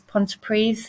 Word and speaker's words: Pontypridd: I'm on the Pontypridd campus, and the town is Pontypridd. It's spelt Pontypridd, but in Welsh, a double D is a TH.